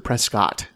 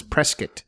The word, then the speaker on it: Prescott: You can tell you're not from here if you pronounce it Prescott. – Yes. – It's Prescott.